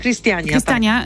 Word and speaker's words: Christiania: A chciałam cię zapytać jeszcze o jedną dzielnicę Kopenhagi, Christiania, nie wiem, czy to się tak wymawia. – Christiania, tak.